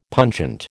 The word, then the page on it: pungent: American English